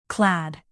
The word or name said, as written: clad